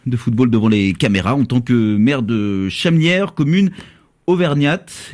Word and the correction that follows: Chamalières: J'espère que je prononce bien. – Chamelières. – Chamelières, pardon, j'avais oublié Chamalière. – Un mot qui traînait. Chamelières. – Chamelières. – Oh, bah, décidément, la troisième sera la bonne.